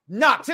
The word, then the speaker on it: Knoxxx: I am your host, Mike Knox. And indeed, I spelled my name with three X's because simply I am the head of the table.